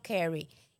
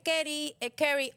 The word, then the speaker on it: curry: Mire, vamos a hacer unos garbanzos estilo curry. ¿Y qué es el curry, o el curry? Que así se escribe. C-U-R-R-Y.